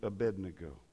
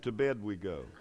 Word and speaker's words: Abednego: Pastor Steve gave a good word earlier, mentioned the three Hebrew children, Shadrach, Meshach, and Abednego. And I heard one preacher who was not as educated as some of the others mispronounce their names. Meshach, Yushach, and to bed we go. That's what he said.